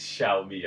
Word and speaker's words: Xiaomi: It's Xiaomi. Xiaomi. I found out today. – Yeah. – It's called Xiaomi.